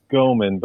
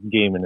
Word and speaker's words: Goeman: Most people say Goeman, but it is technically pronounced Goeman. It's a German variant.